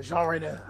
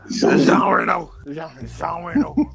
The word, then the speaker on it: Jean Reno: You gotta say it like that. Jean Reno. – Jean Reno.